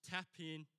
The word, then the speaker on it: tapinoo: The Greek word for humility, for those that don't know, is pronounced tapinoo. Can you say that after me? Tapinoo.